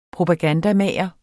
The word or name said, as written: propagandamager